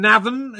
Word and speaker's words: Navan: Well, talking of Ireland, you come from Navan. Is that, am I saying that right? – Yeah.